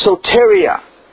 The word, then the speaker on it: soteria: The actual Greek word for salvation there is a Greek word, and it's pronounced soteria. It's S-O-T-E-R-I-A. If you want to put that in your notes, it's soteria. S-O-T-E-R-I-A.